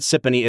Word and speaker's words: Siponey: And if you're looking for something to just like spice up your average summer afternoon on the porch, grab some Sippany if it's pronounced that way. – yeah. So once again, we're calling it Siponey.